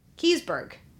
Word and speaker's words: Keseberg: Kiesberg. – K-E-S-E-B-E-R-G. Kiesberg. I think that's how you say it.